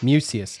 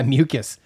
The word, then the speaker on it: Mucius: And like you said, what Isaiah Mucius, how do you say his last name? – Mucius. – Yeah, Mucius.